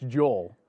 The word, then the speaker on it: Joel: Is it that hard to pronounce the L or realize that there's an L at the end of my first name? It's Joel. Read it again because I shut off after Joe.